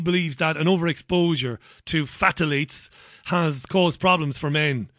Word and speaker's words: phthalates: She believes that, I can't even pronounce this, is it... Oh, Jesus. I knew I'd get into trouble. It's phthalates. Phthalates or phthalates, that's a chemical.